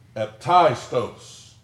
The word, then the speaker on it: aptaistos: I can't pronounce that Greek word. A-P-T-A-I-S-T-O-S. You give it a shot. It's hard for rednecks to say. Aptistos is the pronunciation of it. If they spell it out, aptistos.